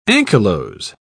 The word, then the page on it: anchylose: British English